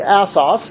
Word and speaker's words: Assos: I'm not sure exactly how you would pronounce that. I just went in and looked in my Bible dictionary or encyclopedia, and it seems like Assos is the way they give it, but I don't think it makes any great difference how we pronounce it. But I guess I will say Assos.